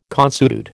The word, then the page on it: consuetude: American English